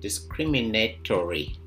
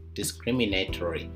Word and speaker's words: discriminatory: First, you have to be able to change this noun into an adjective, which is discriminatory. Discriminatory. You can write that down. Discriminatory. DISCRIMINATORY. So it's like discrimination, but at the end you have TORY instead of TION. And the word is pronounced discriminatory.